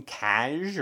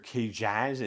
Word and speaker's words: Kcaj: That would be, of course, Yoda and Obi-Wan Kenobi, but also 2 other Jedi, Oppo Rancisis and Coleman Kaj, or Kajaz. It's spelled like Jack, but backwards, K-C-A-J.